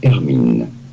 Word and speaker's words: Hermine: The H is definitely silent. Hermine. Hermine. It'd be fine in English to say it as Hermine. Hermine. From French. Hermine. And now you know. Here are more videos on how to pronounce more interesting. – Hermine. The H is silent.